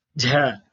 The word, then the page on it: jh: Sanskrit